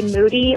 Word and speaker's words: Moody: Hi, this is your biggest fan, Michael Rapaport and G Moody, last name rhymes with duty.